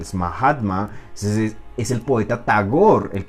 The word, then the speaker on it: Tagore: de India es un poeta que se llama Tagore, Aquí en Colombia dicen Tagore, pero bueno, la R no hay que pronunciarla, Tagore.